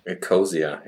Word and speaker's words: Ecosia: And Ecosia, I never really know how to pronounce that. – Ecosia, yeah.